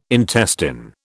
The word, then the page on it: intestine: British English